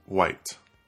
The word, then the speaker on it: wight: And over time, it came to be pronounced white, W-I-G-H-T.